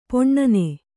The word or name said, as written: ಪೊಣ್ಣನೆ